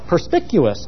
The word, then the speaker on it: perspicuous: Given that Scripture is necessary for salvation, as a light for all of life, given the fact that it's sufficient and perspicuous, perspicuous, I threw out a syllable there, in its redemptive revelation, it is also fully authoritative.